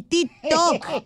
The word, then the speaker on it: TikTok: El TikTok.